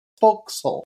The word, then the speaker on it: forecastle: That's why it's not pronounced like castle at all. It's forecastle.